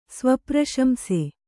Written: ಸ್ವಪ್ರಶಂಸೆ

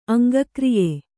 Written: ಅಂಗಕ್ರಿಯೆ

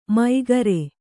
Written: ಮೈಗರೆ

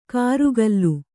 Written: ಕರುಗಲ್ಲು

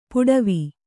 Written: ಪಡಾವು

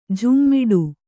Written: ಝೋಮ್ಮಿಡು

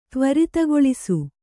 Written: ತ್ವರಿತಗೊಳಿಸು

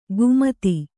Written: ಕುಮತಿ

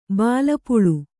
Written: ಭಿಲ್ಲಪಳ್ಳಿ

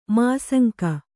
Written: ಮಾಸಂಕೆ

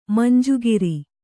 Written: ಮಂಜುಗಿರಿ

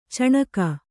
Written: ಚಣಕ

